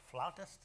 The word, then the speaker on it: flautist: And I thank God for the flutists that the Lord has blessed us with. It's kind of, I guess, unusual to have four accomplished flutists. I think it's flautist is the proper pronunciation.